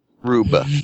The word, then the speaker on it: Rube: that maybe closing the chest stopped a rude Goldberg type. – A rude? He's rude? – Rube. – Okay. – Rube Goldberg.